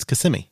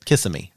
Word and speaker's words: Kissimmee: But some people pronounce it Kissimmee. – Right, because. – Yeah, because that's how it's spelled. Mm-hmm, mm-hmm. It's like, you know. We don't understand how to say native words. – You put the emphasis on the different syllable.